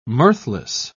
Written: mirthless

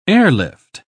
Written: airlift

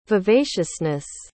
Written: vivaciousness

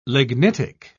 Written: lignitic

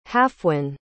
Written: hafwen

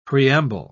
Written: preamble

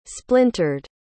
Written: splintered

